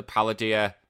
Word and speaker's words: Paldea: It's this huge, like multicolored turtle on its shell, but if you actually look at the Paldea map, is how you pronounce it, the Paldea region.